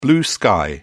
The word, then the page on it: blue sky: British English